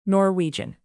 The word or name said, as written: norwegian